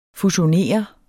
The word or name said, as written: fusionere